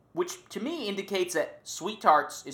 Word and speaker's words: SweeTarts: and an unholy amount of SweeTarts and Spree. – I love that you've committed to pronouncing SweeTarts without the second T.